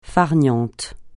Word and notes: farniente: francais

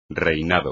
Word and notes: reinado: espagnol

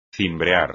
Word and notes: cimbrear: espagnol

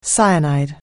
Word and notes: cyanide: anglais

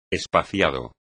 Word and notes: espaciado: espagnol

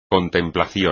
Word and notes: contemplación: espagnol

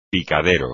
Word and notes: picadero: Spanish

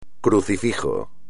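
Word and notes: crucifijo: español

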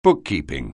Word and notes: bookkeeping: anglais

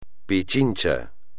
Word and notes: Pichincha: espagnol

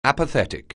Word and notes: apathetic: anglais

